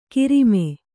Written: ಗರಮ್

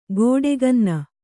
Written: ಗೋಡೆಗನ್ನ

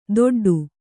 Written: ದೊಡ್ಡು

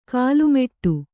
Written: ಕಾಲುಮೆಟ್ಟು

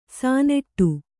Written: ಸಣ್ಣಾಟ

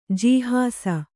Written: ಜಿಹಾಸೆ

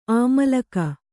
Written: ಅಮ್ಲಿಕೆ

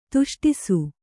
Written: ತಿಷ್ಠಿಸು

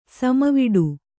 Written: ಸಮವಿಡು

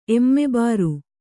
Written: ಎಮ್ಮೆಬಾರು

